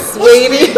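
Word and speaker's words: Saweetie: Now, I'm speaking of Quavo and, is it Sweetie or Sweaty? How you say her name? – Sweetie.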